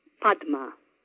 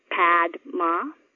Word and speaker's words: Padma: The first name's quite easy, just 2 syllables. Padma. Not Padma, but Padma.